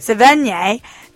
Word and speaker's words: Sevigny: In the past episodes, we reported Chloe Sevigny, is that how you say it? – Sevigny, denying rumors between her and Pauly D.